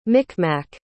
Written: micmac

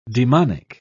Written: demonic

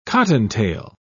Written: cottontail

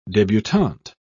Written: debutant

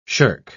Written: shirk

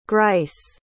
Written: grice

